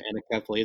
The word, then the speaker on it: anencephaly: Our first child died of a condition called anencephaly, or as some pronounce it, anencephaly.